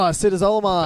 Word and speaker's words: acetazolamide: Oh, acetazolamide.